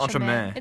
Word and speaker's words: entremets: I wrote entremets.